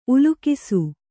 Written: ಉಲ್ಲೇಖಿಸು

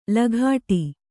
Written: ಲಗಾಟಿ

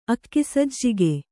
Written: ಅಕ್ಕಿಸಜ್ಜಿಗೆ

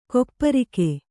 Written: ಗೋಪರಾಗ

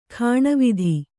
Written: ಗಿಣಿವೋದು

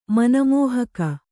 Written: ಮನಮೋಹಕ

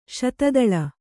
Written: ಶತದಳ

